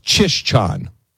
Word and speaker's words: Czyszczan: You'd never know that with the spelling of the name. Czyszczon. It's pronounced Czyszczan.